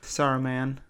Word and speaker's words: Saruman: Saruman. Is that how you say his name? Do you say it different than that? – That's basically how I say it.